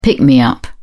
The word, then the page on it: pick-me-up: British English